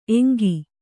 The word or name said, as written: ಏಂಕು